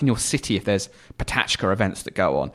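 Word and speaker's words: PechaKucha: I say Petra Kuchas. They're actually pronounced Patachka.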